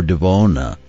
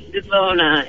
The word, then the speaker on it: Devona: Devona or Devona? How do you say it? – It's Devona. It's with a long O. It's Devona.